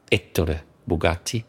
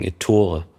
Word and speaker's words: Ettore Bugatti: Und zwar bewusst die Betonung auf der ersten Silbe Ettore Bugatti. Und nicht, wie wir im Deutschen sagen, Ettore.